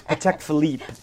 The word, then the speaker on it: Patek Philippe: You got to act a little French. It's Patek Philippe.